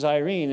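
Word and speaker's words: Irene (εἰρήνη): Now, the Greek word, which is Irene, and the only reason I pronounce it this way is to kind of emphasize that if you know anybody with that first name, Irene, that's what it means.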